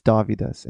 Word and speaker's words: Dovydas: It's D-O-V. And that's Victor. – You know how. Yes, exactly. D-O-V-Y-D-A-S. It's Dovidas.